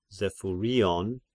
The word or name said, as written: Zephyrion